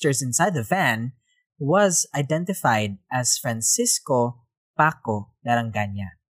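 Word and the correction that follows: Larrañaga: Larañaga. Whoops. – Uh-oh. – I butchered that name. Let me say that again. Was identified as Francisco Paco Larañaga.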